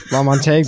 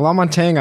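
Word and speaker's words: LaMontagne: But just to touch on the music coming in, it's I picked a song by Ray Laman. How do you pronounce that? – La Montagne. We're kicking it off strong. La Montagne, I believe, is how you say it.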